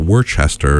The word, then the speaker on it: Worcester: Also, Worcester, just so people know, because the way we're giving it its proper pronunciation, it's actually spelled W-O-R-C-E-S-T-E-R, like a city in England, which is probably pronounced Worcester or something, you know. – Oh, it is. – But this is Worcester. Worcester. In here in Massachusetts, W-O-R-C-E-S-T-E-R.